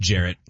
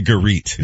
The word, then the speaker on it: Garrett: Pronounced Jarrett. – It's actually Garrett. You're all saying it wrong.